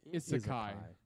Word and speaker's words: Isekai: By the way, it's pronounced Isekai.